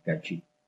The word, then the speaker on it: Cachí: ¿No se dice así? Es una zona... – Cachí. – Es una zona de Argentina, de alguna provincia, de por ahí. – Cachí de Salta.